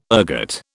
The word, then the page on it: ergot: British English